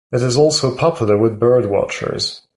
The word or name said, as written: watchers